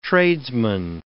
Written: tradesman

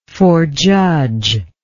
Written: forejudger

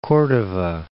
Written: Cordova Island

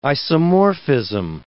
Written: isomorphism

